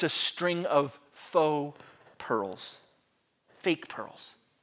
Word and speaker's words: faux: Now the word there, spelled F-A-U-X, is pronounced faux, and it means imitation, artificial, not genuine, fake, false.